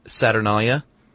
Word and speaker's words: Saturnalia: But in Rome, they celebrated the pretty raucous festival called the Saturnalia from December 17th to 24th. Is that not how you pronounce it? – No, it is.